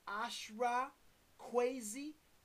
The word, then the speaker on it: Ashra Kwesi: I cite the work of Ashra Kwesi, A-S-H-R-A, Kwesi is spelled K-W-E-S-I.